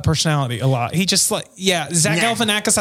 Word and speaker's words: Galifianakis: Zach Galifianakis. – Oh, there you go. Right. It's Akis. Zach Galifianakis. – You just keep the same vowel sound.